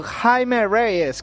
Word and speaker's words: Jaime Reyes: Señores, para las rotativas, película de Blue Beetle con Jaime Reyes, que es como se pronuncia en Estados Unidos.